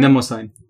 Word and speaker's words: Mnemosyne: Themis, Thea, and Mnemosyne. Do you know how to pronounce that word? – Mnemosyne.